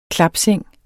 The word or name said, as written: klapseng